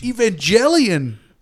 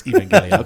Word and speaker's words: Evangelion: Well, it's Evangelion. – It's Evangelion. Okay. – Evangelion. – So, push your glasses up. – So evangelism, evangelic, yeah. – All those are mispronounced. – Or Evangeline. – No, it's Evangelion.